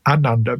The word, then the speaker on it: ananda: Now, the long A, ananda, means great.